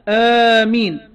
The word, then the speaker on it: آمين: إحداها آمين بالمد بعد الهمزة، بقى في همزة بعدها مد بعدها ميم مخففة من غير إمالة، حط الضابط ده علشان هناك هيقول لي فيه لغة أخرى بالإمالة زي مجريها كده، تمام.